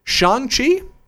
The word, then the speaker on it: Shang-Chi: Anyway, the last story we have, Marvel developing, and I want to make sure I get this pronunciation right, Shang-Chi? – Sure. – You're Marvel asking the developing wrong person. Shang-Chi, you pick the story.